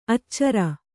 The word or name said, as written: ಅಚ್ಚರಿ